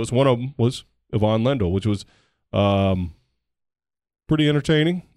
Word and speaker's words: Ivan: One as a tennis video game on a Nintendo, the old school regular Nintendo, used to pronounce his name Ivan Lendl. But Yvonne Lendl,